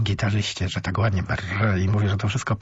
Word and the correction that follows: Barre: A to chyba źle, bo on chyba Bar się wymawia.